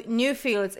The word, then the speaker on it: Nufields: So Nufields is how you pronounce it.